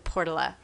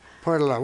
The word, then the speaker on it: Portola: And then there's a bit of controversy about how to pronounce the name of the community. There's different thoughts on that. But I think most of people who've lived there for a long time pronounce it Portola.